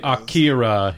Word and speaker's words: Akira: We say Akira here, like Kira.